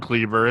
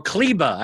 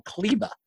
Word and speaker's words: Kleber: You can call him Kleber. It's fine. – Or Kleba. I think actually the R is silent. You have to go Kleba.